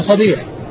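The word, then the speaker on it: صَبيح: فالنعمان بن صبيح والده مفتوح بفتح الصاد وكسر الباء.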